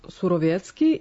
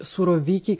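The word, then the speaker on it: Surowiecki: Pa ono što prvo treba reći, to je da Surovjetski ili već Suroviki, kako se on na engleskom izgovara, s obzirom da je čovjek rođen u Americi,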